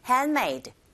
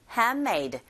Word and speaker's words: handmade: Honey 이 단어 너무 쉬운 단어고 handmade 하지 마시고 원어민의 소리를 가만히 들어보시면 아시겠지만 handmade, handmade 은 사운드, 드 사운드 이렇게 같이 붙어 있을 경우에 드 사운드가 매우 약하게 들리게 됩니다. 그래서 handmade 이런 식으로 발음하시면 되죠.